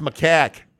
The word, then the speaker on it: macaque: It's pronounced macaque. Exactly macaque. How the soundbite is.